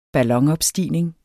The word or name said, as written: ballonopstigning